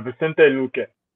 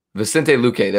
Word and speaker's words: Vicente Luque: How do you say his name? I forget how to say his name. – It's Vicente Luque. – Vicente Luque. There we go. I knew I got the Luque part right.